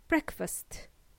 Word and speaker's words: breakfast: It's not pronounced as breakfast or breakfast. It's simply breakfast. Please pronounce the last letter T, okay?